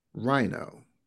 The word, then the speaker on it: rhino: rhino. Since the H in rhino is silent, the H in the clue, every H in the clue is also silent.